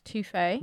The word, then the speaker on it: Tofe: They're called Toffee, but they're spelled T-O-F-E, but it's pronounced Toffee.